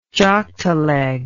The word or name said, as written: joktaleg